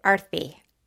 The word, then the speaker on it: Aarti: Like a T-H, Sanskrit T, Arthi.